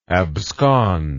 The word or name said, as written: absconder